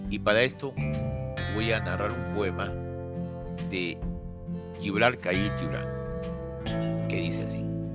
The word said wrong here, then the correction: Gibran: Eh, en alguna en castellano se pronuncia Gibran Khalil Gibran